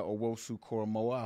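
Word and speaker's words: Owusu-Koramoah: JOK, Jeremiah Owosu-Koromoa, I hope I said it right. – You're damn right you did.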